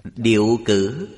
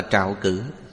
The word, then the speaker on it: trạo cử: Một hiện tượng khác là điệu cử, còn đọc là trạo cử, tức là thấp thỏm, bột chộp, hoàn toàn tương phản với hôn trầm.